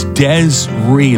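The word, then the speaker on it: Des'ree: A lot of people pronouncing her name wrong. Desiree, Desiree, whatever. It's Desiree. There's a little apostrophe there. D-E-S apostrophe R-E-E.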